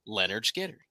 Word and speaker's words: Lynyrd Skynyrd: He was the last living member of the band that played on their debut album, pronounced Lynyrd Skynyrd.